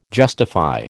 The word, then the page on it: justify: American English